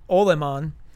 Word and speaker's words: Olemaun: It's the sheriff um, Eben o- I can never pronounce that o- um, Olemaun, O L E M A U N, Eben Olemaun.